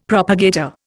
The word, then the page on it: propagator: Indian